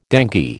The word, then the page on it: dengue: American English